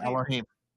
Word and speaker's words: Elohim: So the word, I might butcher it, Elohim. – Okay. Yes, Elohim.